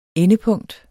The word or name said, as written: endepunkt